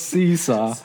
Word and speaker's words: S2-4W: And so my group name is Seesaw, but it's spelled S2-4W. But it's pronounced Seesaw.